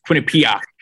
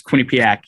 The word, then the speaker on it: Quinnipiac: What I was most hurt by was he mispronounced the name of my school. He said Quinnipiac. It's Quinnipiac University.